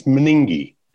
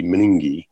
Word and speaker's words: MNGIE: We think it's meningi, is the abbreviation for the mitochondrial neurogastrointestinal encephalopathy disease. Nobody says the whole thing, but it always gets abbreviated to MNGIE, meningi. And it is one of those situations which he recognizes and says it is frequently very delayed in diagnosis.